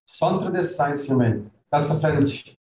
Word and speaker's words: Centre des Sciences Humaines: Centre des Sciences Humaines. – Okay, that's how you pronounce it, not like how I said it.